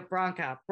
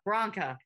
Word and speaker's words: Branca: It is spelled B-R-A-N-C-A. Veronica. We're going to go Bronca. Bronca. – Like Bronco, but.